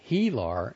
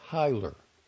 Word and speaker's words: hilar: For example, pronouncing H-I-L-A-R as Healar instead of Hyler.